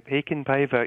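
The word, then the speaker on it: Heikinpäivä: Häkon Päivä? – You're doing very well. Häkon Päivä is the way to pronounce it, and you're almost spot on.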